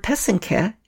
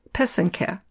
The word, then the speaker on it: pysanky: The word is spelled P-Y-S-A-N-K-A. The word pesenke is the plural. It's similarly spelled, but with a Y at the end instead of an A. So it's spelled P-Y-S-A-N-K-Y. The accent is on the second syllable. An easy way to remember how to say it is that the first and third syllable rhyme. So that's the pe and the ke. Put it all together and you get pesenke.